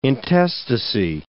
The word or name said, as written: intestacy